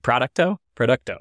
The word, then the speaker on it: Producto: Producto.